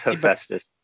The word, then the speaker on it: Hephaestus: You're correct. Nope, it's Hephaestus. Okay, you bastard. – He didn't really pronounce the H, your computer didn't. No, he did. He did it really slowly because he's pronouncing it like you're a moron.